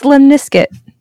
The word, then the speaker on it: lemniscate: It's lemniscate. That is the infinity sign. – Limp biscuit? – Very close. Lemniscate.